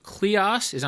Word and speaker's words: kleos: You talk about the concept of kleos. Am I pronouncing that correctly? The old Greek idea? – Yeah, sounds good to me.